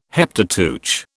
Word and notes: Heptateuch: British English

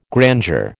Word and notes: grandeur: American English

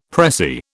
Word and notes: pressie: British English

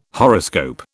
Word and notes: horoscope: British English

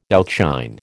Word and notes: outshine: American English

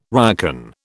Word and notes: ryokan: British English

